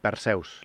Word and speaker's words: Perseus: Bien, eh, estamos hablando de Perseus, ¿lo digo bien? – sí. Eh, Perseus, bueno, a nivel español sería Perseo.